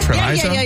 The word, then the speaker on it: Treviso: Treviso? – Yeah.